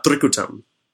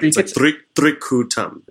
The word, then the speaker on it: Trikutam: Trikutam. – All right, say it without the accent. – Well, I mean, that's how you say it. So, it's like Trikutam. It's like three ku.